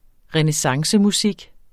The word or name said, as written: renæssancemusik